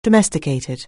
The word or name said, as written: domesticated